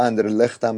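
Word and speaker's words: Anderlecht: خیلی بد بازی کردن رضا، برخلاف بازی وسط هفته شون که تونسته بودن سه هیچ اندرلخت رو توی زمین خودش ببرن، حالا اندرلخت هم میگن تلفظ بلژیکیش اندرلخته، و خیلی خوب بازی کردن تو این بازی و مثلا جایگاه خودشون رو تو صدر جدول گروه خودشون مستحکم کنن.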